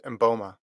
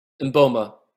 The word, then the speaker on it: Mboma: Dude, Christine Momba. I said her name wrong. Christine Mbote. Mboma? How do I say her last name? – Yeah, I think it's Mboma. – Mboma.